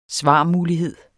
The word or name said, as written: svarmulighed